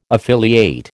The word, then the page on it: affiliate: American English